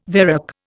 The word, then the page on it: varec: Indian